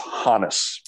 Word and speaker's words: Honus: It's Honus. – Honus, yes. You know, I have a note here to pronounce it properly. – Be insane, but people make it rhyme with bonus. It's the German, he was of German extraction.